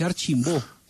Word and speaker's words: Archimbaud: Se escribe Archimbaud, entonces se dice Archimbó, ¿o no? – Pero es peruano.